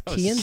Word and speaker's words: Cian: It's Keon. I thought it was Cian. It's Keon. – Keon. – Kean. Really? Kean. – It's spelled learn, Cian. – Learn your Irish.